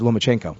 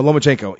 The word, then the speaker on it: Lomachenko: Is Lomachenko? – Yes. – Yeah. So no, Lomachenko.